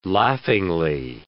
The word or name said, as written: laughingly